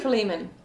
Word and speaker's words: Philemon: Is it Philemon or Philemon? – Philemon. It depends on who you are.